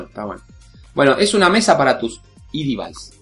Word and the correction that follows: iDevice: ¿Lo dije bien en inglés? ¿E-device? – iDevice. – iDevice. Perdón, tenés razón.